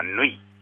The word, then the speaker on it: 縫: そうですね、そこに高岡縫い、高岡、高い低いに岡山の岡、縫いの縫いは縫うという字に殿という字を書いて縫いと読みます。